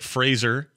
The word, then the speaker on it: Fraser: He goes, by the way, I'm Brendan Fraser. Yeah, that's right. Fraser. That's how you say it. He said it. He made a point of it.